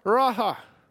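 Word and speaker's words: Raah: But Jehovah Raha, our shepherd, that's how it's pronounced, Raha. And that's R-A-A-H with the certain symbols.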